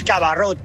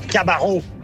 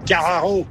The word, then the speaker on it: Cabarrot: Los nombres en francés, en este caso, que estáis pronunciando muchos nombres en francés, todos los nombres que terminan en consonante, no se lee la consonante al final. O sea, no es Cabarrot. La T no se lee, es Cabarrou. Cabarrou.